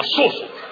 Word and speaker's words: сосуд: И ты все, нет, не сосуд, сосуд. Именно сосуд. Я правильно, я ударение сделал правильно.